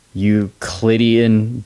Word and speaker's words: Euclidean: When Einstein was 12, he taught himself algebra and, I'm going to butcher this name, Euclidean geometry.